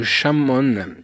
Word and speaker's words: come on: Alors d'autres anecdotes, au niveau des paroles de la chanson Bad, certaines paroles comme Come On sont prononcées Shamon.